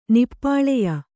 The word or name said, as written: ನಿಪ್ಪಾಳೆಯ